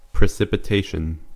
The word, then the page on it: precipitation: American English; /prɪ.sɪ.pɪˈteɪ.ʃən/